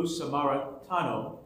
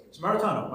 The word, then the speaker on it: Samaritano: But today, we have the privilege of having Lou Samaritano. Did I say your name right, Lou? – Samaritano. – Samaritano.